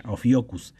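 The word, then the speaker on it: Ophiuchus: La astrología tradicional eliminó un signo llamado Ophiocus, escrito como Opiochus, Opiochus, pero se, pero se pronuncia Ophiocus.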